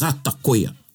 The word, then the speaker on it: ata koia: But ata koya, when you use ata koya, and it's spelt A-T-A, K-O-I-A, but mm-hmm. how you mhm. say it is ata koya.